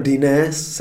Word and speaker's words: Dines: Jsem zakladatel Daniel, jak se čte Daniel? Daniel Dines se to čte, Dines. Ale čte se to dneska i Dines. Různě.